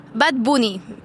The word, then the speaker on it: Bad Bunny: And you taught me something, that you don't pronounce it Bad Bunny. You pronounce it how? – Bad Bunny. – Bad Bunny? – Yes. he also pronounce Bad Bunny. – Okay, so the correct way to say it is Bad Bunny.